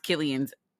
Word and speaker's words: Cillian: That dialect was kicking Cillian, pronounced Killian's ass.